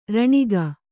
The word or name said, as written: ಋಣಿಕೆ